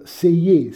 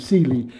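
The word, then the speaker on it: Selye: Hans Seyer, si pronuncia, anche se tutti gli inglesi e gli americani pronunciano Seeley, che è, diciamo, l'ideatore della teoria dello stress.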